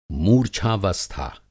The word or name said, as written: मूर्च्छावस्था